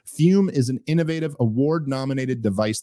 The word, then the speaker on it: Fum: I'm talking about our longtime sponsor, Fume, pronounced Fume, but it's spelled F-U-M.